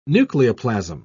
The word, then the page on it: nucleoplasm: British English